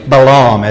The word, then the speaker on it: Balaam: Now this is reference that Peter makes to a man named Balaam, or Balaam as some pronounce it.